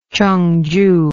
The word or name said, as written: Chungju